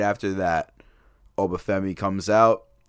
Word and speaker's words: Uba Femi: So Uba Femi, am I saying it right? – Mm-hmm.